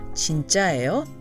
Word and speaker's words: 진짜예요: One other thing to remember if you want to ask whether something is real as opposed to being fake or knockoff, you must use the non-contracted form 진짜예요, four syllables, 진짜예요, not 진짜요, three syllables.